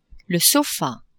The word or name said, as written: sofa